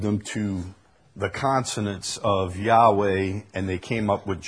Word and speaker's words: Yahweh: The Hebrew letters YHWH, Yod, He, Wah-Hey, YHWH, is probably best pronounced Yahweh, some Yahweh.